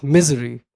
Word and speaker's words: Missouri: The first thing we see is a gas station in Missouri uh, without gas and without power. Back then, uh, because of my accent, I pronounced the name as Misery, which I still do, but because of my experience there, it's miserable.